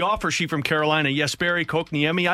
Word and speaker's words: Kotkaniemi: It is Kotkaniemi is how you say that one, but so yeah, wasn't even close.